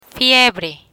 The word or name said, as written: fiebre